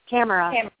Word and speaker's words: Tamara: Is it Pamela? – Tamara. – Can you spell that? – T-A-M-A-R-A. – Is it P as in Paul? P-A-M-A-R-A? – T as in Tom, like Thomas.